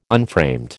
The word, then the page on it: unframed: American English